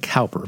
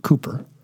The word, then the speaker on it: Cowper: William Cooper, it looks like Cowper, but I've been told it's pronounced Cooper, wrote a poem, a song that you're probably thinking of right now.